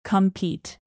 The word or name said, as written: compete